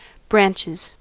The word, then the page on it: branches: American English